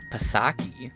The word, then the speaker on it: Psaki: Psaki, I know I'm not saying her name right, but she hates pronouncing the P.